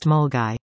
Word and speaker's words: Milngavie: commenced, Milngavie, pronounced Mulgai.